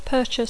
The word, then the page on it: purchase: English